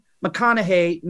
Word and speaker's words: McConnaughey: So Dr. Janyne McConnaughey, no relation to Matthew, but it is an easy way for you to remember how to say her name,